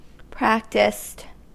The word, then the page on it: practiced: American English